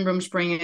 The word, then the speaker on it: Rumspringa: Rumspringa. – Yeah. – What is that? – So that's another one that's a misconception. Rumspringa is not allowed where I'm at,